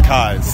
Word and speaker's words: Kies: It's spelled like keys with an E. – A K in front of I, so it's Kies.